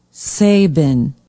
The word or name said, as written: sabin